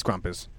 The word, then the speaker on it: Krampus: Is it Krampus or Krampus? – It's Krampus.